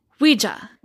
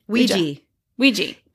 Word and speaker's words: Ouija: And they called it Ouija. Ouija. Am I saying it right? – Ouija. – O-U-I-J-A, right? – You spelled it correctly.